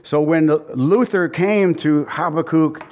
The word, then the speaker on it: Habakkuk: And this monk told him to read the book of Habakkuk. Okay, that's how you say it.